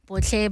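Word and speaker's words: Botlhe: Botle Baloyi on TikTok and on YouTube as well.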